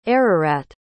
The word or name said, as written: Ararat